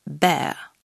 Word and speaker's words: bear: Et dans ce cas, le « r » ne se prononcera pas. « Bear ».